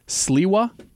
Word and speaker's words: Sliwa: Thank you, Charlie Kirk is urging GOP hopeful Curtis Sliwa. Yeah. Am I pronouncing that right? – Yeah, he used to be on 97.1.